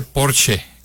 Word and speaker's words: Porsche: Se dice Porsche. Porsche. Sí, eso es importante. Hay un video muy simpático de una alemana que dice, en alemán pronunciamos todas las letras, entonces Porsche, ¿no? Pero de repente llegamos y decimos, ¿de dónde vienes? De Porsche. ¿De dónde? Dice, de Porsche. No, es Porsche. Porsche. Sí se pronuncia muy bien.